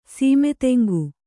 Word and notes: ಸೀಮೆತೆಂಗು: Kannada

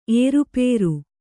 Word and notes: ಏರುಪೇರು: ērupēru

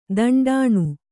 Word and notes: ದಿಂಟೆನಾ: Kannada

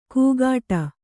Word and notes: ಕೂಗಾಟ: kugāṭa

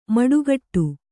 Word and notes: ಮಡಿಕಟ್ಟು: kannada

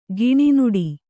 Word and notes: ಕನ್ನುಡಿ: kannada

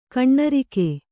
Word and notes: ಘನರಾಗ: ghana rāga

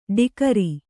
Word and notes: ಡಿಗ್ರಿ: kannada

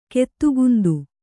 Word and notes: ಗತಿಗುಂದು: kannada

